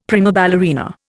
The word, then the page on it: prima ballerina: Indian